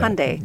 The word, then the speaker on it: Hyundai: Hyundai. Mm-hmm. Hyundai. Mm-hmm. That's how you say it, but mm-hmm. it doesn't look like how it's spelled.